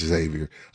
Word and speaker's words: Xavier: I don't think you ever pronounce the X, so it's just Xavier.